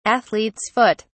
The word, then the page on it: athlete's foot: British English